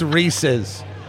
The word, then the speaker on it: Reese's: It's how it's pronounced. It's Reese's. It's Reese's.